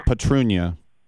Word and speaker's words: Petrunia: No, so it's how do you say it? Petunia? – It's with an R. Petrunia.